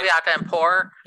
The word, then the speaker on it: caveat emptor: I get it as far as, you know, how do you say that? Caveat emptor. Let the buyer beware, right? – Caveat emptor, Yeah.